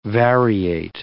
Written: variate

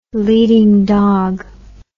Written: leading dog

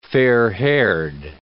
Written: fair-haired

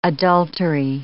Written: adultery

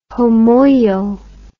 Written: homoio

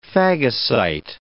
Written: phagocyte

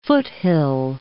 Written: foothill

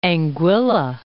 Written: Anguilla

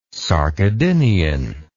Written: sarcodinian

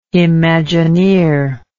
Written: imagineer

